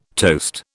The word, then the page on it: toast: British English